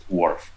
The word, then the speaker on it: WRF: So it spells WRF, but we don't pronounce it WRF, we pronounce it WRF, like Fisherman's WRF.